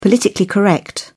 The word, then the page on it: politically correct: British English